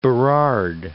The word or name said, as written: Burrard Inlet